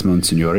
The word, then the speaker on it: Monsignore: Monsignore. Or Monsignor, as you might. – Oh, Monsignor. Okay. – Yeah, in English, Italian it's Monsignore. In French it's Monseigneur.